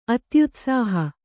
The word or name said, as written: ಅತ್ಯುತ್ಸಾಹ